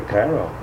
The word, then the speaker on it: Cairo: How do you pronounce it? – I say Cairo.